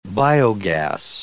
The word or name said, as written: biogas